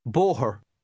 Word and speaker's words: Bóthar: Bóthar. Bóthar. Bóthar. An Bóthar. – There is a slight H sound in the middle and a slight R sound at the end of that word.